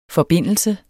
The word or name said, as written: forbindelse